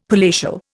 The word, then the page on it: palatial: Indian